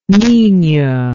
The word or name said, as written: minho